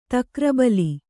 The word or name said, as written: ತಕ್ರಬಲಿ